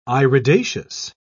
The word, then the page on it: iridaceous: British English